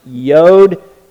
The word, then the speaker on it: Yod: But it's the smallest letter in the Hebrew alphabet, pronounced Yod. It is the Y of the Hebrew alphabet.